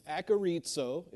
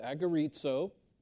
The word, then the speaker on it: agorizo: It's agorizo is how you pronounce it, agorizo.